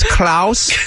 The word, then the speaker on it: Klaus: My first name is Klaus, K-L-A-U-S.